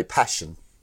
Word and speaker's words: Paschen: Now, the German ships are suffering severe damage, and you're going to be Commander Paschen, who's aboard SMS Lutzow. – I would say Paschen, P-A-S-C-H-E-N.